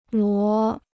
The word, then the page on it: 罗: luó